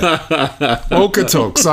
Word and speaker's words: Okotoks: Okotoks.